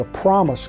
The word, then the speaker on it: Promus: And you know, probably 30 years later, after the company was sold, they became the Promus Company, P-R-O-M-U-S. Promus was how they pronounced it.